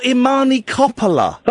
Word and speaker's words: Imani Coppola: Amani Cooper has, has she? Yeah. Who? Your friend from New York. Oh, Imani Coppola. Coppola.